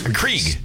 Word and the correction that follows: Keurig: Keurig. – Keurig. – Keurig. Yeah. You don't even know how to say it.